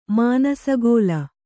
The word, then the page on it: ಮಾನಸಗೋಲ: mānasa gōla